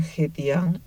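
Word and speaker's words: Jetian (和田): Hoy en día, Jotán, que en chino se pronuncia Jetian, es el nombre de una ciudad de la región uigur de Xinjiang y se encuentra al suroeste de la cuenca del Tarim.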